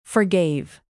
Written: forgave

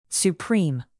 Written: supreme

